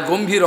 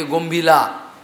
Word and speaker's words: গম্ভীরা: যার গম্ভীর অর্থ কেউ জানতে পারবে না, সেই জন্য গম্ভীলা, অ্যাকচুয়ালি গম্ভীরা বলি আমরা, অনেকে গম্ভীলা বলে, উচ্চারণ উড়িয়া উড়িষ্যা।